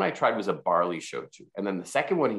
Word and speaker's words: shochu: And first of all, Shochu, S-H-O-C-H-U, and you pronounce it perfectly.